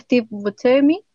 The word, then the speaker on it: Steve Buscemi: Lo pensaron con ese actor, igual que Steve Buscemi, se pronuncia así.